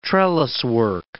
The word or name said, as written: trelliswork